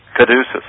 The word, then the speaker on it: caduceus: Caduceus. – Okay, yeah. I was mispronouncing it.